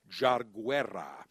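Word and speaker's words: Yarguera: I as soon as you told me, it's spelled with a Y. So if you see it, Lieutenants, it's spelled a Y-A-R-G-U-E-R-A, but it's pronounced Jarguera, estate tobacco, the journey of Jarguera.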